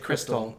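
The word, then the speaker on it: Xtal: You know the Aphex Twin song, X-Tall, is actually pronounced crystal.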